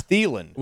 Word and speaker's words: Thielen: Yeah, but I think Joe and I did the right thing there because that's how you say his name. It's Thielen.